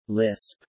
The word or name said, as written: Lisp